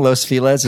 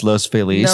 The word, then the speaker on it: Los Feliz: For those of you who are listening, Los Feliz is actually, it should be pronounced Los Feliz, but it's California, we pronounce it Los Feliz.